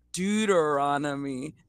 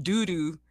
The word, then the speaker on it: Deuteronomy: And he was like, no, it's Deuteronomy, not doo-doo.